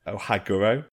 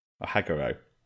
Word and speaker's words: Ohaguro: We're looking at teeth blackening, or I think it's pronounced Ohaguro. Ohaguro. It's O-H-A-G-U-R-O, if you want to Google it.